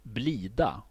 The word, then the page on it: blida: /ˈblìːda/; /ˈbliːˌda/